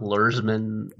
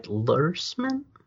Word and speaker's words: Luhrmann: Wait, are we talking about the Great Gatsby novel or like the Baz Luhrmann? – They were talking about the novel.